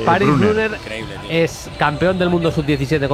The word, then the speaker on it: Brunner: Ahí no tiene problema el Sala en pronunciar, Bruno. – Brunner.